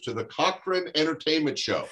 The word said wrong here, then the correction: Corcoran: Yeah, send it to that one, because it's actually pronounced Corcoran.